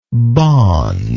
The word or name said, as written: bawn